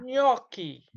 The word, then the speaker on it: gnocchi: Before you impress anyone with this dish, you have to learn to pronounce it properly. It's gnocchi. – Nah. – Gnocchi.